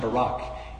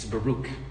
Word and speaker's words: Baruch: It's not Barack, it's Baruch. That's all right though, we mispronounce things.